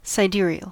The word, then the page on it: sidereal: American English